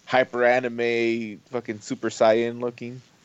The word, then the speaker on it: Saiyan: It's pronounced Saiyan.